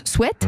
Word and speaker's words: sweat: Eh ben, un sweat, d'abord en anglais, ça se prononce sweat.